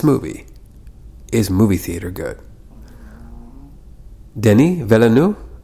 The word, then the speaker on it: Denis Villeneuve: And I believe I'm pronouncing that right because I just heard an interview with him on YouTube, and I'm not trying to be a pretentious fuck who's trying to like make up the pronunciation of some guy's name. He's Canadian. He's like French-Canadian, so it's Denis Villeneuve.